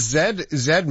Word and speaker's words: Z: Yeah, and in fact, I wouldn't even say that Zed is a British pronunciation. It's Z is the American pronunciation, and Zed is what it is everywhere else.